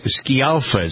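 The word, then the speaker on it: Scialfa: Patty, it's Schialfa is how it's pronounced. – Patty Schialfa. Yep.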